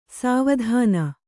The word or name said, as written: ಸುವಿಧಾನ